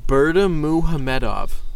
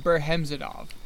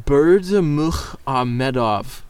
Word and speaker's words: Berdimuhamedov: So the president of Turkmenistan, I really actually need a moment before I pronounce this, Gurbanguly Berdimuhamedov. – I like it. – Wait, yes. I think it's sexy. Gurbanguly Berhemzadov. – No, that's not right at all.